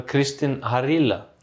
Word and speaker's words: Kristin Harila: Det var Kristin Harila. – Väldigt bra uttalt.